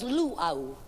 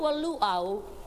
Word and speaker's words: luau: L-U-A-U is a native feast. You see, in our language, we say every vowel that is in the word. This is the one word that is mispronounced so often. Because in referring to a luau, the average visitor who comes to our islands and takes the trouble to learn these words always mispronounces this one. Because they always call a luau a lua. And that's spelled L-U-A, and it makes a difference. Where a luau is a native feast, a lua is a comfort station.